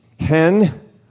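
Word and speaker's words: hen (חן): This Hebrew word for grace, this grace that Noah found in the eyes of the Lord, is, it's kind of pronounced like hen, female chicken. But you need to have a little bit of phlegm. It's like hen, right? Like you're washing, you're trying to fog up your windows. Hen.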